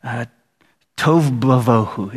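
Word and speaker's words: tohu vavohu: Tov bavohu is what it says. I just like that because it rhymes. I remember that because it rhymes in Hebrew.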